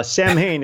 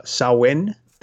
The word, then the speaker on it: Samhain: Samhain is what we call it because that's how it's spelled but in reality, it's called called uh, Samhain.